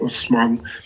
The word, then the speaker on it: Osman: which gave it his name to the Ottoman, or Osman, as the Arabs pronounce it, from 1299 to 1922.